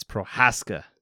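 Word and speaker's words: Procházka: It's Prochaska. – Prochaska. – If he's going to be the champ, you got to get the name right, sir.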